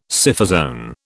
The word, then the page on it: Scyphozoan: British English